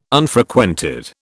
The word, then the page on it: Unfrequented: British English